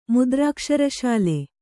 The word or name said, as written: ಮುದ್ರಾಕ್ಷರಶಾಲೆ